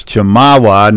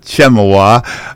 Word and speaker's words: Chemawa: Even though you did mispronounce Chemawa, not Chemawa,